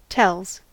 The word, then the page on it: tells: American English